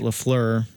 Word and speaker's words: LaFleur: Matt LaFleur is now in. Did I say that right? – I believe so.